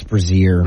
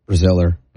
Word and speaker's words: Braziller: I don't think it's Brazier. – Braziller? – No. – Braziller? I mean, Braziller is how it's spelled. – Braziller? – Braziller.